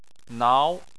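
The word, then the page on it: nao: tone 2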